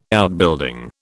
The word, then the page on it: Outbuilding: British English